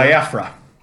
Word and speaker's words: Biafra: I like this version of Jello Bafia, uh, or is it Bofia? Bafia? – Biafra. – Ba- Biafra. I always say it wrong.